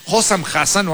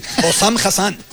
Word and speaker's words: Hossam Hassan: Vamos a escuchar al entrenador egipcio, Hossam Hassan o algo así. – Hossam Hassan.